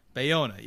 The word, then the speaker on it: Bayona: But hold on, how do you say his name? Bayona. – Bayona. – Bayona. Yeah, there it is.